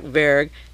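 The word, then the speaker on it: Vergès: Jack Verg, V-E-R-G-E-S, it's pronounced, doesn't think he'll go to trial.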